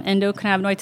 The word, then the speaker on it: endocannabinoid: You've talked a little bit about the endocannabinoid system? Is that how you pronounce it? – Endogenous cannabinoid system, endocannabinoid system for short.